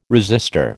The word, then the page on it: resistor: American English